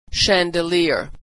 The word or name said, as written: chandelier